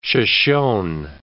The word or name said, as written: Shoshone